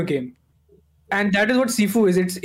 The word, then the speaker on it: Sifu: I'm not mispronouncing Shifu. It is Sifu.